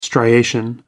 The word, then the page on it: striation: American English